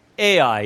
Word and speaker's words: Ai: And then we talked about up here where Ai is, or Ai, depends on how you say it.